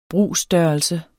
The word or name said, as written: brugsstørrelse